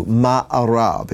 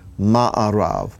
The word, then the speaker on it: ma'arav: In fact, the word for west in Hebrew, ma'arav, it's spelled mem ayin resh beit, ma'arav.